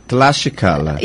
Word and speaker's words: Tlaxcala: é Tlaxcala. – Tlaxcala.